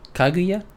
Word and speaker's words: Kaguya: Oh, I wonder, he wouldn't like Kaguya? Did I say that right? – Yeah, because it's literally, well, I think he could.